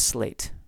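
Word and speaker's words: Sleat: You also have the MacLeods of Slate. I know it's spelled Sleet, but I believe it's pronounced Slate.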